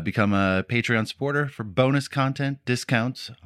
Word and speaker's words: Patreon: But I'm pretty sure it's Patreon because it's like a patron.